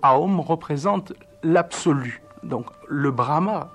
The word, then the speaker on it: Aum: Tous les mantras sont nommés, résumés, si vous voulez, par, au départ, une syllabe, la syllabe « Aum ». Aum, qui s'écrit soit O-M ou bien A-U-M, Aum, donc Aum, qui est...